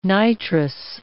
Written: nitrous

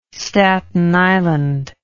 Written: staten island

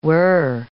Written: we're

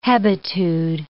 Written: hebetude

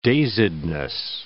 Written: dazedness

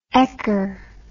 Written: ekker

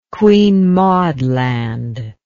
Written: Queen Maud Land

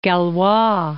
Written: Galois

